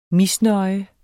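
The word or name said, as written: MISNØJE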